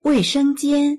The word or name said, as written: wei4sheng1jian1